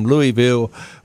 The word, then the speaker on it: Louisville: But isn't that how they say it? Is it Louisville? – Yeah, Louisville. And that's one of the ways.